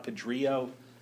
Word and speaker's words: Pedrillo: Not Pedrillo, but Pedrillo because it rhymes with. Come on, work with me here. You can rhyme. Come up with a word that rhymes with Pedrillo. Two syllables. If you're sleepy, you might put your head on it. All right, good. And it's also something that oceans can do. They can billow.